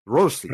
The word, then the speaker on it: Rossi: And roll the R, which I can't. – Rossi? – You can. Rossi. – Rossi. Okay.